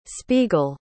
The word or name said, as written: spiegel